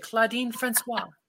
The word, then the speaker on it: Claudine Francois: Claudine Francois.